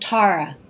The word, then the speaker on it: Tara: It's actually pronounced Tara.